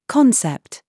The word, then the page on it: SAUCE: British English